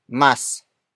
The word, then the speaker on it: mas: Word for word it's mas mahiyain siya kaysa sa akin.